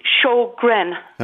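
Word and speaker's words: Sjögren: And it's pronounced Sjogren.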